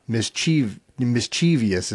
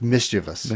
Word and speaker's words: mischievous: The correct pronunciation is three syllables, not mischievous is what people will say. – Right. – It's mischievous.